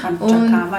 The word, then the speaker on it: Panchakarma: Habe ich es überhaupt richtig ausgesprochen? – Panchakarma ist, ja, genau. Fünf Handlungen heißt es, ist Sanskrit, ja.